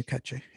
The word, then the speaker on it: Katché: One of my very favorite musicians is Manu Katché. I hope I'm saying that right. I've never said his name. – That's correct. Yes. Manu Katché.